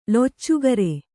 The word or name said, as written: ಲುಚ್ಚಾಗಿರಿ